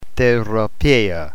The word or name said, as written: therapeia